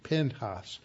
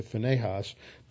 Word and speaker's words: Pinchas: Pinchas in the Hebrew. That's where, if you've been around, been in Mexico, you'll hear that name, Pinchas. And that is the actual Hebrew pronunciation. We've anglicized it to Phinehas.